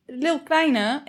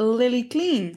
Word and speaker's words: Lil Kleine: En we gaan even terugkomen op de DM's die we hebben gekregen van mensen die dingen verkeerd uitspreken, zoals Lil Kleine, a.k.a. Lily Clean.